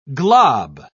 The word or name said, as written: glob